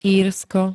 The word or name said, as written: Írsko